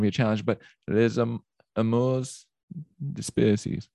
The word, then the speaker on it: Les amours dispersées: How would you pronounce the title of it again? – Les amours dispersées. – Les amours dispersées. – Yeah, quite good.